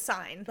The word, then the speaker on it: Syne: I thought it was Odd Legs Zine. – No, like you're pronouncing it wrong. – It's, well, that's how it's spelled, but it's pronounced sign.